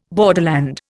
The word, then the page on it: borderland: Indian